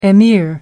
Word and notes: emir: American English

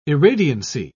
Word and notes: irradiancy: British English

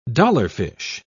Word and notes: dollarfish: British English

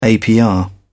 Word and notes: apr: British English

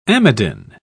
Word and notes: amidin: British English

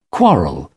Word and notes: quarrel: British English

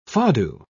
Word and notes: fado: British English

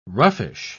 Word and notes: roughish: British English

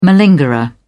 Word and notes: malingerer: British English

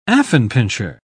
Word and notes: affenpinscher: English; British English

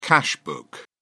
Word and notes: cash book: British English

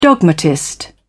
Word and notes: dogmatist: British English

